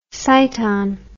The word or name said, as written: cyton